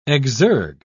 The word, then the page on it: exergue: British English